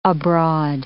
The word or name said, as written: abroad